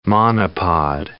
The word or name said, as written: monopod